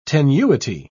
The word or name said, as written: tenuity